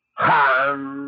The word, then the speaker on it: Ham: Han se escribe con las letras H-A-M. Sa se escribe con las letras S-A-H. La H suena siempre como J.